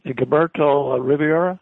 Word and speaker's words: Gilberto Rivera: And Gilberto Rivera, is that how he pronounces his name? – That's correct. Very good on your Spanish there.